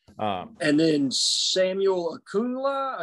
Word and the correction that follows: Okunlola: is that yeah. how you say his name? – Okunlola. The offensive tackle from Massachusetts.